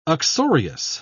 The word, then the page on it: uxorious: British English